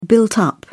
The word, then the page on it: built-up: British English